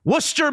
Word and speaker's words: Worcester: Worcester, Massachusetts. Some might call it Worcester. It's spelled Worcester, but it's pronounced Worcester, Massachusetts.